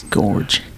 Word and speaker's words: Gorge: Tallulah Gorge. – George. The Gorge. Yeah, it is Gorge.